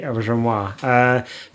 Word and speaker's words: Auberjonois: Aubergenois. – Okay. – It's French. – Well done. – Thanks. Yeah, René Auberjonois.